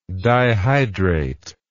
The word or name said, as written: dihydrated